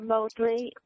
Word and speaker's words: Motley: Tara Mosley, Stanton Mountain Road. – Or could you spell that? – M-O-T-L-E-Y.